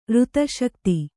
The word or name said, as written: ಋತಶಕ್ತಿ